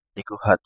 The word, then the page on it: Nikuhatu: Kapingamarangi